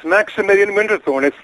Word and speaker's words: Maximilian Winterthorne: We'll see if I said it properly. In real life, though, he is Gordon Thomson. Gordon Maximilian Winterthorne. Am I right? Am I close? Is it? – Yes, Maximilian Winterthorne.